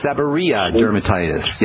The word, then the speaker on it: seborrheic dermatitis: Seborrheic dermatitis, yes.